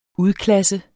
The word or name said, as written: udklasse